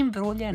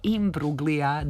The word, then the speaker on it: Imbruglia: A ako se pitate kako se pravilno izgovara, slušali smo naše kolege i koleginice, nije Imbruglia nego Imbruglia,